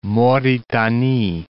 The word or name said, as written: Mauritanie